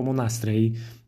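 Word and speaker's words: Monastrell: E, zachęcam Was do sięgania po o Monastrej. pisze się Monastrel, to pewnie w, w, opisie odcinka to, to napiszę dokładnie.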